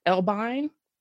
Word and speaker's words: Elbein: But the story that I did on snake handling was inspired by this bitter Southerner story called The Pentecostal Serpent by Asher Elbein. – Um, yes. Learned how to pronounce his last name, Asher Elbein.